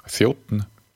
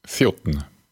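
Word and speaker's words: þjónn: So here we have a waiter or waitress, thjótn. So this word is like thjótn. So it's spelled with two N's, but that those two N's often create this dn sound. So it's thjótn, thjótn. I know it's tricky, but do your best.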